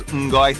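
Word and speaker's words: 唔該: Mgoi, msai.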